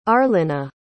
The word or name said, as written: arlena